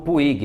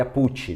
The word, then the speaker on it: Puig: Aqui a gente faz a, a pronúncia como puig, mas na verdade o correto seria put, né?